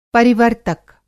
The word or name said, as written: परिवर्तक